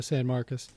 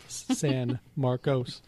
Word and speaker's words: San Marcos: I think I can extend my Kyle stories into San Marcos. You would think it's M-A-R-C-U-S, but it's C-O-S, but locals just say it like a guy named Sam Marcos, but it's spelled San Marcos.